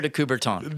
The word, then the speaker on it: de Coubertin: Say his name for me again. De Coubert. Say it again. – Baron Pierre de Coubertin. – De Coubertin. There we go.